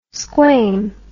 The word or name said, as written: squam